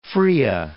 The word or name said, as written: Fria, Cape